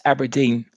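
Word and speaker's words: abrdn: In their press release, when they did the rebrand, they actually specifically specified you have to pronounce this as Aberdeen.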